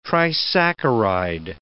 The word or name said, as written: trisaccharide